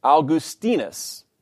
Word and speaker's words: Augustinus: As we have seen, Augustine's Latin name is properly pronounced Augustinus, with the accent on the penultimate syllable.